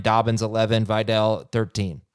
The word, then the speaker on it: Vidal: I keep saying Vidal, which is, by the way, how I do believe it is pronounced. You might be looking at the spelling and going, Adam, you're an idiot, but I heard it pronounced Vidal like 100 times, so I'm rolling with that.